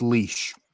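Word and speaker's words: Laois: The most remarkable county is that it took me ages to work out how to pronounce leash, because it's not spelt like leash.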